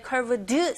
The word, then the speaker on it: curved: 근데 사실 road로 넘어갈 때 curved 사운드 거의 안 들리죠.